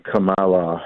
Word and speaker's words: Kamala: So this is today's America under the Biden Kamala Harris regime. And I appreciate you pronouncing her name correctly, Kamala.